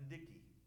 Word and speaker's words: Didache: And this book was called the Didache. Some people pronounce it the Dicky.